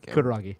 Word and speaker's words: Kutaragi: Then, Ken Kutaragi.